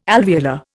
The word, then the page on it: alveolar: Indian